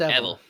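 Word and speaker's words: Evelle: When they get home, they try to settle into their new life when High's old prison mates, Gail and Evel, is that how it's said? – Yeah, I think it's Evel.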